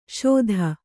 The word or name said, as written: ಷೋದಾ